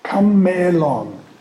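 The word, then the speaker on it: Kamelon (κάμηλον): The word for camel in Greek is kamelon, a long e. Kamelon. The trouble is that already in New Testament times, the long e in Greek was pronounced e.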